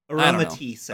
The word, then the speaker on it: Aromatisse: Next up, Aromatis? Am I saying this right? – Aromatisse.